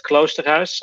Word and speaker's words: Kloosterhuis: It's Robert Kloosterhuis, and I really don't expect the average English speaker to be able to pronounce it at all.